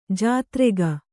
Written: ಜಾತ್ರೆಗ

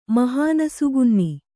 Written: ಮಹಾನಸುಗುನ್ನಿ

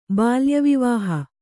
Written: ಬಾಲ್ಯವಿವಾಹ

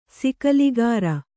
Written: ಸಿಕಲಿಗಾರ

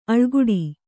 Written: ಅಳ್ಕಾಡು

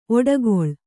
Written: ಒಡಕಲು

